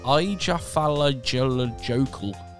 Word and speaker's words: Eyjafjallajökull: Uh, you had the eruption of, I'm going to try this, Eyjafjallajökull the yeah, Iceland. You said it, you yeah, said it right.